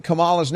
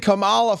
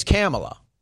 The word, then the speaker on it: Kamala: Nobody's intentionally mispronouncing Kamala's name. She's been Kamala forever, and now you're saying it's Kamala.